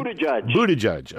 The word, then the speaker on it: Buttigieg: Mayor Buttigieg, is that how you say his name? It's South Bend? – Buttigieg, okay.